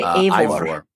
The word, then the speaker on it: Eivor: E-I-V-O-R. – I would say Eivor. – Eivor. – There's a guy Eivor at work whose name is Einar. So I'd say Eivor.